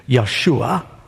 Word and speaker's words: Yeshua: The name Joshua is, we're used to saying the more Hebrew-like pronunciation. More literally, we believe it's Yeshua. And, you know, in English we say Joshua.